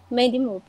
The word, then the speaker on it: Mandy Moore: Maddie, ai, eu esqueci como fala o nome dela, gente, de Rebecca. Como pronuncia? É Mandy, Mandy Moore. Mandy Moore, pronto.